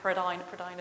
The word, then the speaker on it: Prydain: This is Welsh, so I had to check with Jeff the pronunciation of Prydain. Prydain is the Welsh word for Britain.